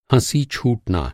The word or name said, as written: हँसी